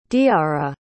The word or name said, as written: diara